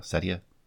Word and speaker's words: Satya: So, Microsoft CEO Setya. I don't know how to pronounce it. Satya. Anyway.